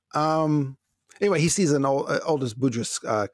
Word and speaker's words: Budrys: Brandon Melvin on Facebook sees an Aldous Budrys. Am I pronouncing that name right? – I have heard people who seem like they should know say it differently, but most people I've heard say Budrys.